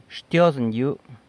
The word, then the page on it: Xtyozën yëbu: sanlucasquiavini